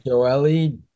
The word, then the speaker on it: Joely: So it came out yesterday that Jolie? I don't know how to say his name. – Joely. – Joely. That's what it is.